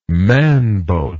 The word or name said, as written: manbote